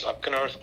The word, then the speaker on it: Opgenorth: I'm here with Tim Opgenorth. Is that how it's pronounced? – Perfect.